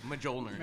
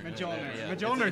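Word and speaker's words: Mjölnir: Hammer named Mjolnir. I don't know how to pronounce that. – Mjolnir. – I think you nailed it. Mjolnir. – Mjolnir is totally right.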